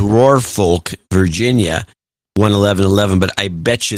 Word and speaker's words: Norfolk: Norfolk is the way it's supposed to be pronounced, to be honest about it.